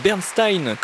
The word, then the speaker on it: Bernstein: Une œuvre que vous connaissez sans doute, Candide de Leonard Bernstein, ou plutôt Bernstein, comme l'annonçait avec la vraie prononciation américaine Mitchell Merrill,